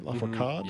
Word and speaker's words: Lafourcade: We're going to hear Soledad y El Mar from Natalia Lafourcade. Is that right? Lafourcade? – Yeah.